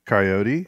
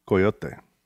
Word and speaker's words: coyote: I'll say coyote, but I mean, down here, you would be just as apt or likely to hear coyote. – Mm. – Oh. Mm. This guy says, I grew up in rural area Kansas where the word coyote was pronounced in the two syllables form, coyote.